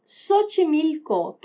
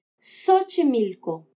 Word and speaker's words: Xochimilco: Y otra palabra es Xochimilco, que empieza por X. Cuando esto pasa, esta letra suena como S. – Xochimilco.